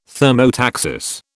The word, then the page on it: thermotaxis: British English